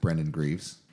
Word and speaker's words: Brendan Greaves: We are here in the record room with Brendan Greaves.